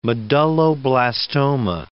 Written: medulloblastoma

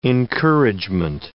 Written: encouragement

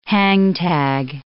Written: hangtag

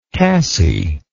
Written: cassie

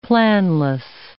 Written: planless

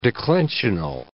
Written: declensional

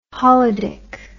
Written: holidic

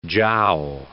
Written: jow

